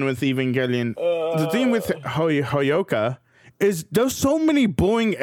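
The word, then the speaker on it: Hyouka: Or is that, that's not even how you pronounce it, is it? Hayoko? – Nope. Your cheat sheet's doing wonders. – Hyoka? – Yeah. Hyoka. Hyoka is going to be at number 11, behind Evangelion.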